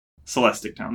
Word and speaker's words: Celestic Town: How is this town pronounced? – Celestic Town? – Celestic Town. There we go.